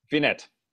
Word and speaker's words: Finet: Finet, perfect.